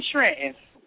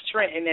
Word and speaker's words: Trenton: You know you're from Jersey when you pronounce Trenton as Trenton. People out of Jersey say Trenton. They pronounce the T.